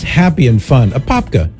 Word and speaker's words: Apopka: Apopka, Florida? – That sounds perfect to me.